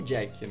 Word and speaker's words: Jacques: Eu não sei se é Jacques que fala, porque é aquele famoso nome francês, francês é. que a gente nunca sabe como pronuncia, que é Jacques, não sei, é o Jack, né? É o Jack é, francês.